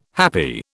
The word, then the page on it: happy: British English